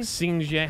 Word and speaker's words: Xinjiang: Xinjiang. How do I say that? – You're right.